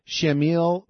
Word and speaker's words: Shamil: All right, so now I want to get to this Hamil Basayev. Oh, I mispronounced his name. There's an S at the beginning of it. It's not Ham, it's Sham. Sham, S-H-A-M. Shamil Basayev.